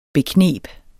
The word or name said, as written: bekneb